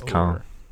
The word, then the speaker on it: Cannes: Now, am I saying the city right? This is in is it Cannes or Cannes? – It's Cannes. Cannes. Okay, this is in Cannes in France.